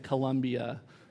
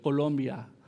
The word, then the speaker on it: Colombia: My wife specifically told me I need to say it like that because I said Colombia first. Colombia.